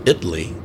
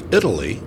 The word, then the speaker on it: Italy: The town of Italy, spelled like the country Italy, but pronounced Italy here, and Honey Grove.